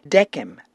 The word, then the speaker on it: decem: According to the classical Latin, what the ancient Romans spoke, the Roman numerals are decem, viginti, triginta, quadraginta, quinquaginta, sexaginta, septuaginta, octaginta, nonaginta, and centum.